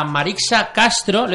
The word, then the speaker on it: Marixa Castro: Hoy tenemos eh, en el estudio a Marixa Castro.